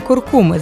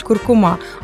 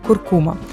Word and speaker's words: куркума: Так-так, саме куркуми з наголосом на другий склад. Хоча найчастіше ми чуємо, як кажуть, куркума, але це ненормативний розмовний варіант. Правильно, куркума.